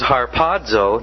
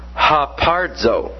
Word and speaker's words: harpazo: By the way, you'll notice the word is harpazo, not hapardzo. Sometimes you may hear it pronounced hapardzo. It's not hapardzo, it's harpazo.